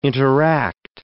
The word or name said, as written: interact